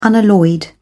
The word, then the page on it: unalloyed: British English